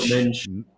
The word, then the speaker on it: Mendes: I don't know how they pronounce it. – Mendes. – He said, Nuno Mendes. – So not Mendes, which I've been calling it. I've been butchering it. – Apparently, you can say Mendes too. – Yeah, you can say both. But in Portuguese, it's Mendes.